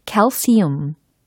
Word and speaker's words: calcium: 칼슘에 해당하는 영어 발음도 아실 수가 있었죠? calcium, calcium. 이라고 하시면 되겠습니다. 아하, 칼슘. 요거하고는 차이가 있어요. 칼슘이 아니라 calcium, calcium.